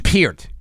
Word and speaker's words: Peart: Peart or Pert? – Peart. It's Peart. It's actually pronounced Peart.